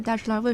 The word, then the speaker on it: 大栅栏: 其实到现在，大石栏为什么叫这个读音，其实没有官方的说法。 – 嗯，就是我们从小到大的时候，因为大栅栏它其实不符合那个老北京话里面的那个读音，所以有一种说法是它属于老北京的那个方言的一个变通。